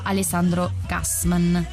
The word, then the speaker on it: Alessandro Gassman: Praticamente parla di un professore di filosofia, diciamo molto fuori dal comune, che viene interpretato da Alessandro Gassman. Spero si dica così. – Sì, pronuncia perfetta.